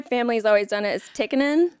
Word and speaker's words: Tikkanen: So that's like the Americanized way, but apparently when it's Finnish, which is, that's where I'm from, where my dad's from 100%, you have to pronounce both consonants. So it's technically Tikkanen,